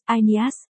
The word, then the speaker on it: Aeneas: Bốn, trong thần thoại Hy Lạp Enea, tiếng Hy Lạp Aeneas, Aeneas, phát âm như y, ni, át, là một anh hùng của thành Troia, là con trai của Anchises và nữ thần tình yêu Aphrodite.